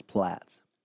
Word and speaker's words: Platz: John Platz, or is it Plots? – The way that it's spelled, I would say 98% chance it's Platz.